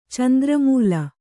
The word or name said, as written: ಚಂದ್ರಮೂಲ